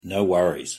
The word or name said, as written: no worries